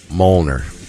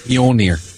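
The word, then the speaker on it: Mjölnir: And then the last one is, Captain America is worthy to wield Mjolnir. I know I just mispronounced that. – Mjolnir. Mjolnir. Say it with me.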